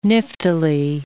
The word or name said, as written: niftily